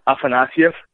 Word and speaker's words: Afanasyev: The most of the time they say like just like you, Afanasyev. – Yeah. – But actually, it's Afanasyev.